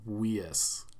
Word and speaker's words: Weisz: I just say her name Weisz like a German. What a nerd. – Yeah, it's Rachel Weisz. Come on. – Rachel Weisz, okay. – We speak English here.